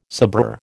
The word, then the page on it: sabreur: American English